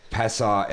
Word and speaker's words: Passau: Passau, emphasis on the U.